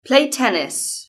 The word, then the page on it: play tennis: British English